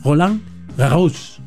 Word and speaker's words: Roland Garros: Y en la embajada de Francia me dijeron, en la francés se pronuncia la S final. Por lo tanto, es Roland Garros.